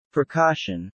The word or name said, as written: precaution